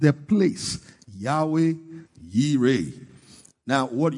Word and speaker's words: Yahweh Yireh: Now, but in the real pronunciation in the Hebrew is Yahweh Yireh.